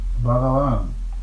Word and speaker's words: Bhagavan: The accent is on the first and last syllables. The second slurred over. Bhagavan.